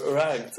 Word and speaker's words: Rugged: Το Rough and Rugged ή Rugged, δεν θυμάμαι πώς προφέρεται αυτό. – Rugged.